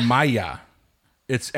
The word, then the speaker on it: Maillard: Maya. It's actually pronounced. It's spelled Maillard. M-A-I-L-L-A-R-D.